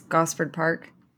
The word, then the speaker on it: Gosford Park: since we just watched this like yesterday was Gosford Park. Is that how you say that? – That is how you say it, yes.